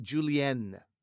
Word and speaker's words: julienne: Also, when you see the two N's and the E, you can hit that landing, buddy. Julienne is how it's pronounced.